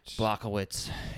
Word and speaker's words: Błachowicz: Błachowicz? I don't know if I'm saying that right. – Błachowicz.